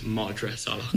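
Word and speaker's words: Madra Salach: Sorry, it's Madras Salak. – Madras. – I was asked. – Have I been saying it wrong the entire time? – I don't know. I didn't. That's the first time I've caught it. I was asked to intervene with pronunciation. – You are. I told you. – We haven't made it easy on you with Irish. – Madras Salak. Madras Salak.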